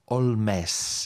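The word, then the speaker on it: Holmès: Els pares eren irlandesos, Augusta Holmes, però ella mateixa va decidir nacionalitzar-se francesa i afegir un accent obert a la E de Holmes. Per tant, ella pronunciava el seu nom com Augusta Holmes.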